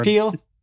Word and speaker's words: Peele: How do you say his last name? I might be butchering that. – No, I want you to try it again because I like Key and Peele? – Yeah, there you go.